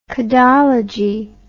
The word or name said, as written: codology